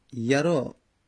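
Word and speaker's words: يرى: یرآ، خب عرب یرا تلفظ میزنه، یرآ تلفظ نمیزنه.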